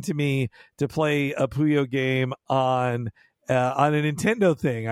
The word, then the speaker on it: Puyo: The way you're pronouncing the game is really what Kirby tends to say as a language. – Oh. – The game is Puyo. – Puyo. I was saying Puyo Puyo.